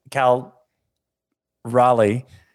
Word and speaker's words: Cal Raleigh: no, that's that's actually what I was thinking too when I looked at the data because Cal Riley, Cal Raleigh, did I say that right? Yeah, it's like the capital of North Carolina.